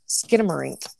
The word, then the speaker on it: Skinamarink: It's called, let me see if I can pronounce this right, Skinamarink? – Skinamarink, okay.